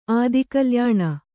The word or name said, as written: ಆದಿಕಲ್ಯಾಣ